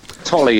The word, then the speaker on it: Tolley: Am I pronouncing your name correctly, first of all? Tolley. – Tolley, yes. Tolley. Yeah.